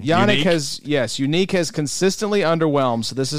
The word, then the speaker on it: Yannick: Once the stuff about his name being pronounced Unique came out, it's all been downhill.